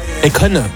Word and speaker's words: Akon: Akon. – Akon, ouais, pardon, je prononce ça à la française. – Akon, c'est pas pareil.